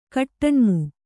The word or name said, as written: ಗೂಟನಾಮ